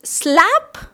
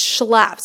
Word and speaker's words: Schlapp: And I married the gringo, and she couldn't pronounce his last name because the Cubans, they were like, so is it Schlapp? Because they can't do the sh sound. And I was like, no, Mom, it's Schlapp. So she'd go, oh, shrimp, Schlapp.